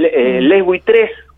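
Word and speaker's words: Les Buitres: Les Buitrés, que se pronuncia de esa manera, sí, es como una especie de alegoría humilde al Lelutier.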